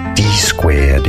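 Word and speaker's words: Dsquared: D squared is how it's said, because it was founded by two D-squared.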